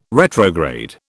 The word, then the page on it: retrograde: British English